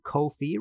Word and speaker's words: Ko-fi: If you liked this show and want to support future episodes, please head over to Ko-fi, rhymes with no fee.